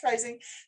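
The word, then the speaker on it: Reising: And then you have to look at how my name is spelled, J-U-L-I-E-R-E-I-S-I-N-G, but it is pronounced rising.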